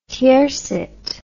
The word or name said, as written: tiercet